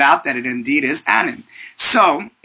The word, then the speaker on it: Annan: Kofi Annan. And I read a fantastic little clip that your uncle stated that the last name rhymes with Cannon, so it's Annan and not Anan.